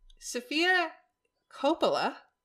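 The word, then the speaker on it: Sofia Coppola: Sophia Coppola, right? – Uh-huh.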